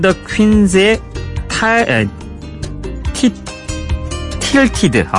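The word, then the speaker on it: Tilted: 타이틀이라고 순간 착각을 해가지고 예, 틸티드라고 예습 때 읽어놓고서도 이런 실수를 저지릅니다.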